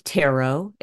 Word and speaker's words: tarot: I say tarot, and it really doesn't matter how you pronounce it, just so long as you don't rhyme it with carrot.